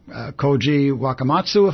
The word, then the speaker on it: Wakamatsu: Okay, so, once again, this director, Koji Wakamatsu, if I'm saying his name correctly. – That's correct.